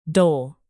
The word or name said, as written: dole